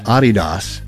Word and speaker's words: Adidas: This, by the way, is a partnership between a designer, Rick Owens, and Adidas, which is the right way to say that, by the way.